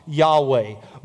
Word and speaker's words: Yahweh: And we know from that that the word would be pronounced Yahweh.